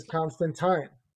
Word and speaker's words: Constantine: It's been mispronounced, but the proper pronunciation is Constantine.